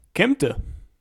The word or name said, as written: Kempten